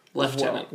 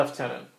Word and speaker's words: lieutenant: And the way that the British pronounce the word lieutenant. – Lieutenant. – Well, lieutenant. – Yeah. – There's no F.